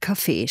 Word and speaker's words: Kaffee: Das Wort Kaffee spricht er selbstverständlich mit Betonung auf dem zweiten Vokal aus. Alles andere wäre undenkbar.